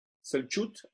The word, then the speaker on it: Selçuk: Hay una ciudad ahí pegadita que es habitada, la que ya leímos hoy, Selçuk,